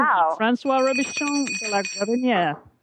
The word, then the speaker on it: Guérinière: Was it François Boucher or was it François Robichon de la Guérinière? – It was Guérinière.